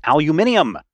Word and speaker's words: aluminium: And then when you go out and other people are like, no, it's pronounced aluminium, then you really figure out the correct way.